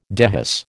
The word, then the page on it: dehisce: American English